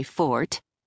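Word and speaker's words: forte: "Oh, yes, you might say real estate is my fort," I said, dropping the second syllable in case he was actually a Brit who knew the proper pronunciation.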